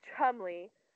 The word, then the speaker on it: Cholmondeley: So Chumley, it's pronounced Chumley, but it's spelled, and I shit you guys not, it's spelled C-H-O-L-M-O-N-D-E-L-E-Y. Cholmondeley. – Like so many syllables.